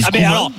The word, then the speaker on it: Koeman: Okay, dans ce cas-là, Koeman, faut qu'on dise Koeman aussi. – On dit Koeman, bien sûr.